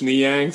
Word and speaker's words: Niang: Gorgui Dang. I'm not Dang. I'm saying Niang.